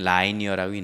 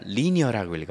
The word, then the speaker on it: linear: 그럼 이때는 라이니어라고 읽는 게 아니라 리니어라고 읽어요. 그것만 발음만 조심하세요.